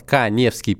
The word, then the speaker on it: Каневский: Подписывайтесь на мой Инстаграм Эдуард Каневский, пишется моя фамилия через А.